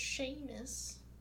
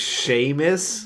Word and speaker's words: Seamus: It's Seamus. – His name's Seamus? – But it's spelled Seamus, because that's how Irish people...